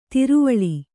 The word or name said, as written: ದ್ರಾವಿಳ